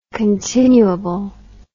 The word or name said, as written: continuable